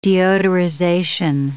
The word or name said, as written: deodorization